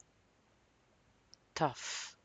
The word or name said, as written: tough